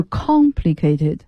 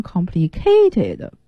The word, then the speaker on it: complicated: complicated 就是很复杂的哈，就注意大家这个单词的读音，它的重读音节在第一个音节上，是 complicated。因为我教学生的过程中老听见有同学把它发音成 complicated，不对，重音不在 k 上面，是在 con 上面，复杂的形容词。